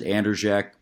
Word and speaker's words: Andrzejek: I pronounce it Andrzejek, but if you see me, just do your best.